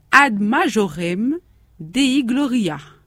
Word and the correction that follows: gloriam: J'ai bien prononcé, Maria? – Oui. – Gloriam, oui.